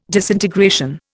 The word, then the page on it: disintegration: Indian